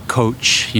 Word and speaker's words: Koç: Koç University.